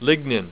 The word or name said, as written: lignin